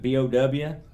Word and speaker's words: Bow: Um, they the uh, they were in East London and had an office at a place called Bow. Have you ever heard of that? How do you spell that? B O W. – Huh, or no. Bow.